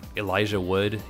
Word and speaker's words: Elijah Wood: and the Elijah Wood,